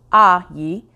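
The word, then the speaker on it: Ah Yi (阿姨): I remember this lesson from my early interviews with an Ai Yi, a nanny to watch Rainey while I worked, pronounced Ah Yi.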